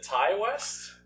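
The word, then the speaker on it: Ti West: Is it Ty West? – I believe that's how you would pronounce it.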